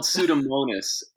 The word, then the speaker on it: Pseudomonas: Yeah, it's called Pseudomonas, and it's out of this genus and species designation that you learned about in seventh grade biology.